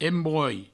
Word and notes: embrolh: embroi

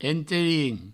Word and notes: enterin: provençal

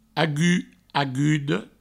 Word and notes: agut, aguda: agut, agudo

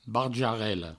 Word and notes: barjarela: barjarello; Provençal

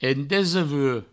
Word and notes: endesevuech: des-e-vue

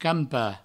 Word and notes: campar: provençal; campa

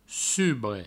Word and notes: subre: provençal

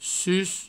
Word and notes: sus: provençal

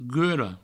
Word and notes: gula: gulo; Provençal

